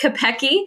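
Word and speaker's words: Kopecki: It is the best worst last name. Looks like Kopecky, but it's pronounced Kopecky.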